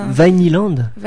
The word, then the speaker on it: Vinyland: Vinyland. – Vinyland. – Ça fait un peu comme Disneyland, en fait.